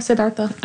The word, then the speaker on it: Siddhartha: I was thinking about when they talked about Buddhism and they said that when Siddhartha, is that how you say it? – Yeah, Siddhartha.